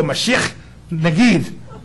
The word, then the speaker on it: Mashiach Nagid: If you're Hebrew, you got to spit like they do and go, Mashiach Nagid. That's how they say it.